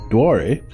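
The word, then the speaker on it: Doire: The Irish pronunciation, Duare, means oak woods.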